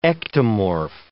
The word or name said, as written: ectomorph